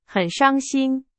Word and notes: 伤心: Chinese

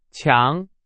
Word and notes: 强: Chinese

